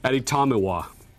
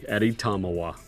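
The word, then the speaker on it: Adetomiwa: Adi Tomiwa. I wrote this out phonetically. Adi Tomiwa. That's the first name.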